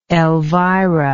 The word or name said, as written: elvira